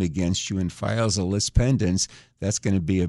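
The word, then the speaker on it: lis pendens: One of them is called lease pendens. And even though it's pronounced lease, it's spelled L-I-S. Pendens is P-E-N-D-E-N-S.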